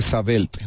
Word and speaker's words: Sabelt: Tienes estos asientos que estábamos comentando hace rato, que depende de la gente cómo lo quiera pronunciar. Si lo vamos a pronunciar en español, lo correcto es decir Sabelt, ¿no?